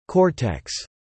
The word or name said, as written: cortex